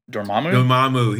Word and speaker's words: Dormammu: Dormammu.